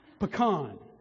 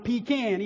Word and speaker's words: pecan: Now, it depends. It's actually regional. I'm from Mississippi, so if you go like Mississippi, Arkansas, Texas, it's the correct pronunciation, pecan, pecan, not pecan. It's not pecan either. That's, never mind. It's pecan, okay?